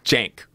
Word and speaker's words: Cenk: It's Cenk, right? Yeah. A J, right? – Yeah.